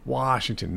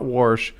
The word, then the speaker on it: Washington: And I'd be like, Dad, it's Washington, not Warsh, Washington.